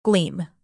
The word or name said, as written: gleam